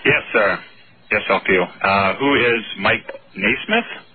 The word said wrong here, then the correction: Nesmith: You both had it wrong. You have to pronounce the names correctly. – Michael Nesmith. – Nesmith. There you go. It's an S and a myth.